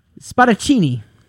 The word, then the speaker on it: Spadaccini: Spadaccini. Actually, I didn't butcher it because it's Italian.